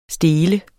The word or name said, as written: stele